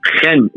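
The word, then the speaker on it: Chen (חן): Miren, el término hebreo para gracia eh, es es Chen, Chen, se escribe Chen como C-H-E-N, Chen, ¿verdad?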